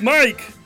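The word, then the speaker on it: Mic: My name's Mike.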